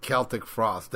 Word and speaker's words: Celtic Frost: And I want to go into some goddamn Celtic Frost. You know what it is. This is motherfucking Dethroned Emperor. – I love that you pronounced it right. Celtic Frost. That's right.